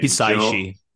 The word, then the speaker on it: Hisaishi: Hisaishi.